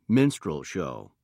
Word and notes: minstrel show: British English

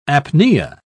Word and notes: apnea: British English

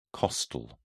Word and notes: costal: British English

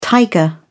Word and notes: tiger: British English

